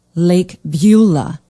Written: lake beulah

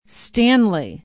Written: stanley

